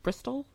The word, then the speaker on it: Bristol: I don't know if I'm saying that right. Bristol? – I think that's correct, yeah.